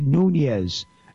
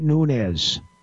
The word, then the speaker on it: Nunes: And by the way, Schumer, it's pronounced Nunes, not Nunes.